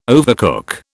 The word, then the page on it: overcook: British English